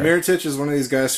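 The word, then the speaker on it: Mirotić: I actually, if I had a close second to Andrew Wiggins, it would definitely be, I believe it's pronounced Mrotić. From Chicago. Miritich. – Yeah. – Miritich. It's not pronounced Mrotić at all.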